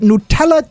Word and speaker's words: Nutella: This is Santa's 4-ingredient Nutella cheesecake dip. – So that's how you say it. – Yeah. – Oh, that's how you say it. – Nutella. – It's new. – And not nut.